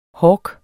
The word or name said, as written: hork